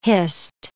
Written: hist

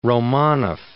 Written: Romanoff

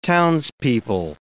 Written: townspeople

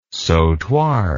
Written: sautoir

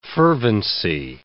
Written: fervency

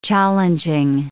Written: challenging

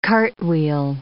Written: cartwheel

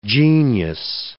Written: genius

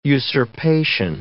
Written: usurpation

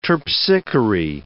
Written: Terpsichore

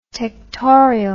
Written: tectorial

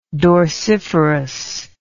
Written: dorsiferous

